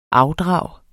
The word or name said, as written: afdrag